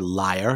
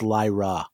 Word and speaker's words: Lyra: Well, you'll find neither here, liar." And he's like, "No, it's Lyra. That's you're pronouncing it wrong.